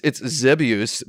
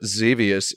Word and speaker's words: Xevious: Yeah, it's Zebius, but Zevius is correct in English, right? – Yeah, that's what I said. – Because it rhymes with Devious.